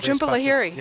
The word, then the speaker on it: Jhumpa Lahiri: Oh, Jhumpa Lahiri.